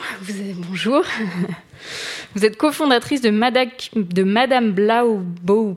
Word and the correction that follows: Blackbow: Production. Je l'ai mal, très mal prononcé. – Madame Blackbow.